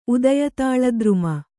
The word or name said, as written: ಉದಯತಾಳದ್ರುಮ